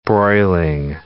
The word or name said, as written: broiling